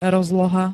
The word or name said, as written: rozloha